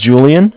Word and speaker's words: Julian: My name is Julian. It's spelled J-U-L-I-A-N.